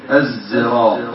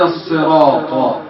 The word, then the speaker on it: الصراط: نعم ثم ذكر ابن أبي حاتم قول الله تعالى الصراط المستقيم، اهدنا الصراط المستقيم، فكما علمنا علماء القراءات قديما أن العرب لها لهجات في حرف الصاد في قراءة الصراط، فصح عن العرب ألا تقول اهدنا الصراط بالسين، وصح عن العرب أنهم ينطقون بالزاي الزراط. فالصراط هي لغة قريش، والصراط بالسين والزاي لغات عربية صحيحة. فأنت تقول اهدنا الصراط بالصاد،